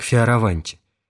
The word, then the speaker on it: Фиораванти: В следующем году приехал из Венеции архитектор Аристотель Фиораванти, или, как советует произносить его фамилию отец Пирлинг, Фиораванти, и признал нужным уничтожить всю старую работу до фундамента включительно.